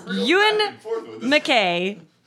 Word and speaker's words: Ewan McKay: Ewan McKay. And it is phonetically spelled out, you win, McKay.